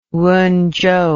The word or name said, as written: Wenzhou